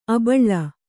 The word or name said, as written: ಆಭೀಲ